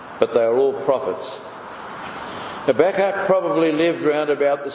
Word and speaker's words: Habakkuk: Some say Habakkuk, I say Habakkuk. So that must be the right pronunciation.